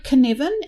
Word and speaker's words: Cynefin: The word Canevin, and if you're hearing me talking it, you're probably thinking it's something beginning with K. It's a Welsh word. It begins with C, so it's spelled C-Y-N-E-F-I-N.